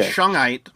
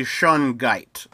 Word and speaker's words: Shungite: Yeah, the first four letters and then the second four letters is how you pronounce it. Because I want to say Shungite, like where the G is silent. Like when I look at it, but it's actually Shungite.